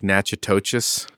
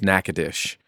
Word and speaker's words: Natchitoches: If you look up the town on a map, it looks like Natchitoches. – Okay. – But it's pronounced Natchitoches.